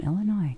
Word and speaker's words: Illinois: From Illinois.